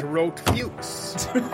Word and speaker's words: Rot Fuchs: and we went with the German pronunciation of it instead of Red Fox. We went with Red Fox. – What? – Go ahead and say it. – It was Rot Fuchs.